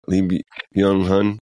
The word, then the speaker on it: Lee Byung-hun: Lee Byung-hun.